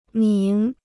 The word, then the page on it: 名: míng